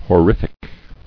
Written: Horrific

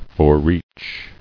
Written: Forereach

